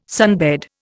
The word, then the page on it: sunbed: Indian